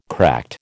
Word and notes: cracked: American English

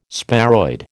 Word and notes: sparoid: American English

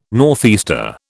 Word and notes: northeaster: British English

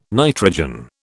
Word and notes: nitrogen: British English

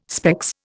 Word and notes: specs: Indian

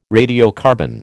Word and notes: radiocarbon: American English

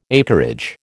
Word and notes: acreage: American English

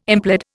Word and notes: implead: Indian

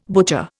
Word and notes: butcher: Indian

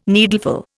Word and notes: needleful: Indian

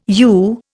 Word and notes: u: Indian

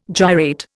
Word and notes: gyrate: Indian

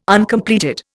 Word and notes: Uncompleted: Indian

